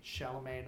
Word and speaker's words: Chalamet: Chalamet, not Chalamet.